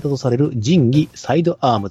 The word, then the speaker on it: 神器: えー、問いの 17 は、えっ、ー、と、仁義、えっ、と、神の器と書いて、仁義と読む、はい、あのタイトルになっております。